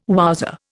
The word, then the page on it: wowser: Indian